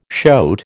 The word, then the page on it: shoat: American English